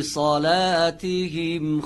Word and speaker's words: صلاتهم: الَّذِينَ هُمْ فِي صَلَاتِهِمْ خَاشِعُونَ. – الَّذِينَ هُمْ فِي صَلَاتِهِمْ خَاشِعُونَ. – در کلمه صلاتهم صاد درشت باید تلفظ بشه، ولی لام نازک. صلاتهم اگر بگیم صلا غلط میشه.